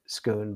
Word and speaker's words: Scone: It's pronounced Skoon, but it sounds like it's written like scone.